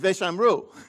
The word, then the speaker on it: Veshamru: If we were in Israel, it's Veshamru. Okay? The accent is on the U.